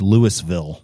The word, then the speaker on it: Louisville: We need to say that word properly. It's Louisville. – Louisville. – It's Louisville. – They almost say it like it's Louisville. – Louisville. – Yeah. Well, it's Louisville. – Yeah. I guess. – Yeah. Pronounce it out. Louisville.